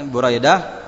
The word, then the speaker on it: Buraidah: Kemudian rawi yang berikutnya adalah Buraidah, ya, Buraidah. Taib bidami awalihi, ini untuk yang menghafalkannya, jangan salah membacanya dengan apa? Bidami awalihi, dengan dhamma pada awalnya, bu, ya, jangan salah baca ba, ya, tapi bu.